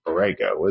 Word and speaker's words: Borrego: Borrego is how you say it.